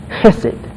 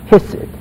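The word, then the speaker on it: Hesed (חסד): 那用英文来拼的话呢，应该是 C-H-E-S-E-D. – To spell it in English, oh, yeah. C-H-E-S-E-D, Hesed, Hesed. – Hesed is the sound.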